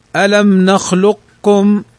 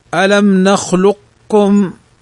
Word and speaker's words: ألم نخلقكم: قال وتدغم القاء، وتدغم القاف في الكاف إدغاما كاملا نحو ألم نخلقكم، ألم نخلقكم،